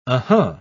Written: uh-huh

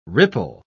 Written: ripple

